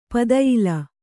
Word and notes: ಪದಯಿಲ: padayila

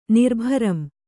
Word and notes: ನಿರ್ಭರಂ: Kannada

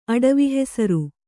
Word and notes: ಅಡವಿಹೆಸರು: Kannada

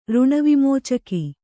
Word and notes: ಋಣವಿಮೋಚಕಿ: řṇavimōcaki

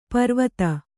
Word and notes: ಪರೀವಾದ: parīvāda

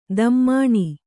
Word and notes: ದಮ್ಮಣಿ: dammaṇi

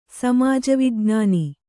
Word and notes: ಸಮಾಜವಿಜ್ಞಾನ: kannada

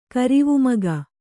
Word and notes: ಕರಿವುಮಗ: Kannada

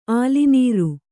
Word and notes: ಆಲಿನೀರ್: kannada

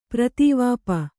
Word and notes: ಪೃಥಿವಿಪ: přrhivi pa

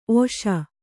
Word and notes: ಒಸಿ: kannada

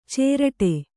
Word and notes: ಚೇರಟೆ: cēraṭe

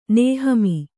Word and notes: ನೇಹಮೀ: nēhamī

